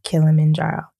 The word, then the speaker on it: Kilimanjaro: and I'm going to say this right, y'all, Mount Kilimanjaro. – Yes, did I say it? You got it.